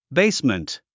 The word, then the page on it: basement: béismənt; ベイスメント